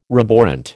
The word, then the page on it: roborant: American English